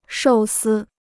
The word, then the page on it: 寿司: shòu sī